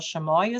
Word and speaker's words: Chamois: Well, first of all, Chamoy is spelled like chamois, C-H-A-M-O-I-S.